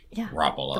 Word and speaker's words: Garoppolo: I mean, his name is Gar, not Gur, anyway. – Garoppolo. – It's not G-R-oppolo, it's G-A. Garoppolo.